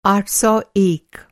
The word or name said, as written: आठ सौ एक